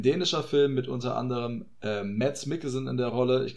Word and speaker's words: Mads: Wir können auch gerne ihn weiterhin Mads nennen in der deutschen Form. Aber ich meine, Mads ist die richtige Aussprache im Dänischen.